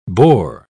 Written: bore

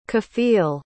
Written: kafeel

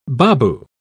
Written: babu

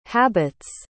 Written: habit's

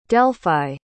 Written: delphi

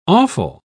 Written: awful

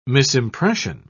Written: misimpression

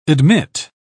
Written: admit

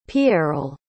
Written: pierrel

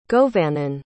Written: govannon

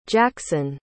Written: jackson

